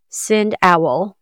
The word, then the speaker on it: SendOwl: I've known people that have used this, and it is spelled exactly how it sounds. SendOwl. Owl, like the nocturnal bird out behind your house in the tree.